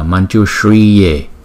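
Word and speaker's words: Mañjuśriye: Chữ S có dấu sắc chúng ta đọc là S S S, và chữ Y có dấu gạch ngang chúng ta đọc kéo dài hai nhịp so với chữ Y bình thường không kể, không có dấu gạch ngang, thì chúng ta đọc là Manjushriye Manjushriye Manjushriye.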